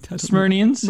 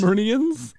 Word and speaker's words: Smyrnians: Smyrnians? Am I saying that right? – Smyrnians. – Smyrnians.